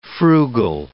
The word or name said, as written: frugal